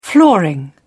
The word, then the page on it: flooring: British English